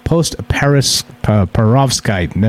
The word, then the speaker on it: perovskite: When it cools, bridgmanite forms into another material, a mineral called perovskite.